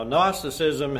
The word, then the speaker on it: Gnosticism: Now, Gnosticism has a silent G. There's a G at the beginning of the word Gnosticism.